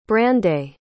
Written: brandais

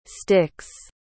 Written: sticks

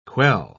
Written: quell

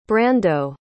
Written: brando

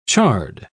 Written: chard

